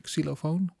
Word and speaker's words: xylophone: I had long sessions where I looped a little bit of those is that the right in English? Xylophone? Right. It's long sessions of that xylophone looped, which, you said it right, really glued us together, I guess.